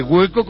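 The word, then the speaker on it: Guheko: Oye, pero lo de hueco, ¿cómo lo escribiste mal? – Bueno, es la forma de que... – internet para hacer turismo activo, hueco, tal y como suena.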